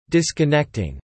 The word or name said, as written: disconnecting